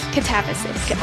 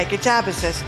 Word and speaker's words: Katabasis: And as we said before, she's got all these other great books, Babel and the Necessity of Violence, Katabasis? I say it right? Katabasis? – Katabasis. – Okay. Okay, Katabasis.